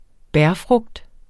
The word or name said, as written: bærfrugt